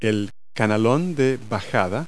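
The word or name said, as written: downspout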